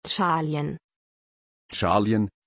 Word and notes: charlien: German

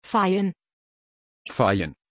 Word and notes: vajén: German

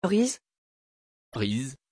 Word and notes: reese: French

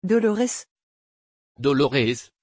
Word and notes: Dolores: French

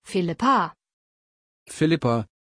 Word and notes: phillipa: German

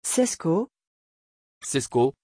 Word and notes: cesco: French

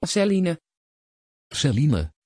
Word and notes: celine: Dutch

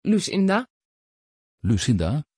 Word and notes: Lucinda: Dutch

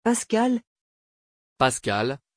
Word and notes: pascale: French

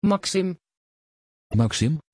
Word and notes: Maxim: Dutch

